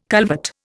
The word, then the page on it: culvert: Indian